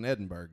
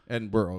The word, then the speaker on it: Edinburgh: And like you said, it's terrible in Edinburgh yesterday. – Edinburgh. – Okay. – Got to say it right.